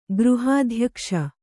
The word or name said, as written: ಗೃಹಾಧ್ಯಕ್ಷ